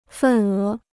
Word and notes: 份额: fèn é

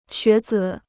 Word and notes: 学子: xué zǐ

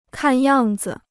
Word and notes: 看样子: kàn yàng zi

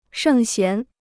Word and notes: 圣贤: shèng xián

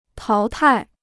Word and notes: 淘汰: táo tài

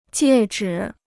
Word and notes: 戒指: jiè zhi

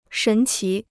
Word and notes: 神奇: shén qí

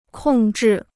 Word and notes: 控制: kòng zhì

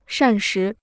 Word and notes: 膳食: shàn shí

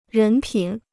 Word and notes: 人品: rén pǐn

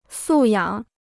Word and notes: 素养: sù yǎng

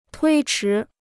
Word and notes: 推迟: tuī chí

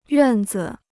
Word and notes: 院子: yuàn zi

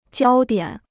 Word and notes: 焦点: jiāo diǎn

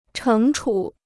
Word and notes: 惩处: chéng chǔ

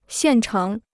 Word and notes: 现成: xiàn chéng

